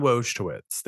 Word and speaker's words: Wojtowicz: So John, I'm going to try to pronounce this correctly. Wojtowicz. – I was going to say Wojtowicz. – That sounds right. John Wojtowicz. There we go.